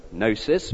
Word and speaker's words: gnosis: As you know, it comes from the Greek word gnosis, spelt with a G, with a negative prefix a, meaning not.